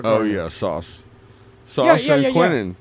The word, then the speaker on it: Quinnen: Quinnen, is that how you say it? – Quinnen Williams.